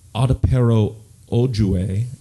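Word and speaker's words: Adepero Oduye: And really the only award acclaim that this film got was when Adepero Ojue, and I don't know if I'm mispronouncing her name. – I think you did a good job.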